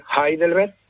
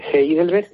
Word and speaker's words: Heidelberg: se dice Heidelberg, pero que se escribe con H, Heidelberg, y es una ciudad, de verdad, muy bonita.